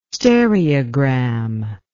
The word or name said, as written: stereogram